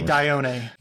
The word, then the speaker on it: Dione: And that took us closer to Rhea, Hyperion, and Dione or Dione. How do you say that? – I would say Dione.